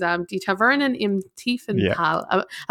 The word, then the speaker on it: Die Tavernen im Tiefental: So the first game that's kind of on my radar and I haven't shut up about for the entire month is Die Tavernen im Tiefental. Am I pronouncing that right? – You're pronouncing it very well.